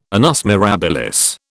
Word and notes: annus mirabilis: British English

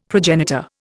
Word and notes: progenitor: Indian

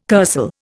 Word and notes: kursaal: Indian

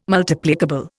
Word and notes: Multiplicable: Indian

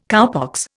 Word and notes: cowpox: Indian

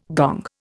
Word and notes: gong: Indian

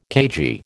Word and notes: cagey: American English

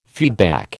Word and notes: feedback: American English

